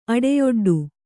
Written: ಅಡೆಯೊಡ್ಡು

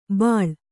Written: ಬಾಳ್

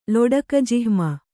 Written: ಲೊಡಕಜಿಹ್ಮ

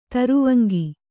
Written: ತರುವಂಗಿ

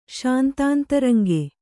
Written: ಶಾಂತಾಂತರಂಗ

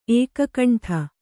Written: ಏಕಕಂಠ

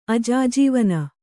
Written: ಅಜಾಜೀವನ

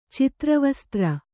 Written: ಚಿತ್ರವಸ್ತ್ರ

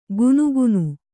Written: ಗುನುಗುನು

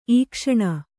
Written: ಈಕ್ಷಣ

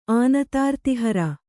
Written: ಆನತಾರ್ತಿಹರ

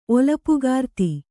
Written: ಒಲಪುಗಾರ್ತಿ

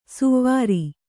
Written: ಸುವಾರಿ

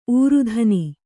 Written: ಊರುಧನಿ

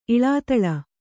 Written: ಇಳಾತಳ

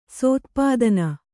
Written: ಸೋತ್ಪಾದನ

